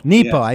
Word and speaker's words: Nepo: Mark, is it pronounced Nepo or Nepo? – Nepo. Yes.